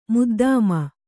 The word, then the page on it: ಮುದ್ದಮ್: muddām